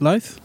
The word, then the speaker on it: Louth: Louth.